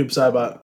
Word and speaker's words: Noob Saibot: I was a big fan of playing Sub-Zero and also Noob Saibot. I don't know how you say it, but. – Yeah, it's definitely Noob Saibot.